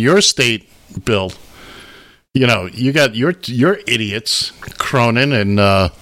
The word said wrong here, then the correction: Cornyn: And Cornyn. – Cornyn, excuse me. I'd hate to mispronounce his name as he's being let out in handcuffs.